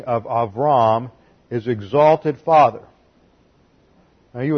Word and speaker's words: Avram: Abraham was originally named Avram. And that is spelled in the Hebrew, it looks like this. And the B there is a soft B and translated almost like a V.